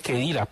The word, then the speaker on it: Cheddira: Se dice Chedira, pues, pero se escribe con CH.